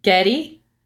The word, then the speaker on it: Getty: Getty. Am I saying that right? – Getty? – Yeah. – Yeah, G-E-T-T-Y. Like a get together.